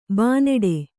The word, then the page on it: ಬೈನಾಡು: baināḍu